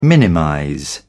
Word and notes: minimize: British English

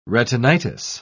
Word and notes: retinitis: British English

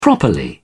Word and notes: properly: British English

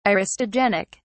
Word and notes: aristogenic: British English

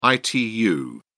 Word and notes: itu: British English

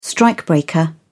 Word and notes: strikebreaker: British English

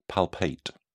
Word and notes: palpate: British English

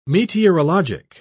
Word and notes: meteorologic: British English

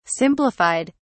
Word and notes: simplified: British English

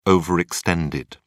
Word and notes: overextended: British English

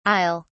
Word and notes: i'll: British English

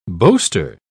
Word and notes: boaster: British English

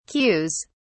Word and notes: queues: British English

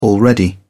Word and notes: already: British English

